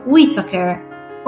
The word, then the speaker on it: Whitaker: Tutti i colori del buio di Chris Whitaker, o Whitaker, come preferite. A me viene più naturale dire Whitaker, ma si scrive Chris, quindi C-H-R-I-S, e poi W-H-I-T-A-K-E-R.